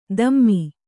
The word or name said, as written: ದಮ್ಮಿ